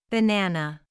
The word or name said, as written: banana